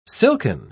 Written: silken

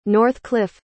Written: Northcliffe

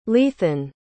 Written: leathan

